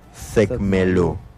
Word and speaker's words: sesecmelú: Y finalizamos con la letra U, que se pronuncia sesecmelú.